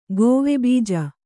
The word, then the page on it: ಗೋವೆಬೀಜ: Kannada